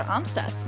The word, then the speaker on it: Amstutz: And you can find me on all social platforms at Sandra Amstutz.